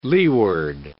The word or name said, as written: leeward